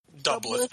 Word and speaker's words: Dublith: Doublet, but yeah. – So like Dublin? – Yeah.